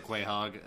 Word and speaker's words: Quahog: I said Quahog.